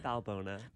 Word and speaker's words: Balbona: Balbona. – Balbona. I think for so long I left off the N. I was like, Balboa.